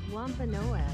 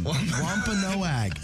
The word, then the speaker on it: Wampanoag: Wampanoag. – Yeah, that one. Wampanoag.